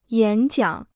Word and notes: 演讲: yǎn jiǎng

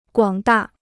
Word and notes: 广大: guǎng dà

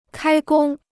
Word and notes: 开工: kāi gōng

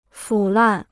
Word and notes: 腐烂: fǔ làn

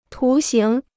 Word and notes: 图形: tú xíng

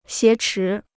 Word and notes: 挟持: xié chí

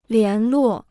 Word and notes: 联络: lián luò

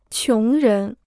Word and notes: 穷人: qióng rén

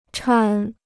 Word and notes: 搀: chān

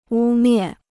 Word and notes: 污蔑: wū miè

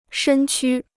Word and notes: 身躯: shēn qū

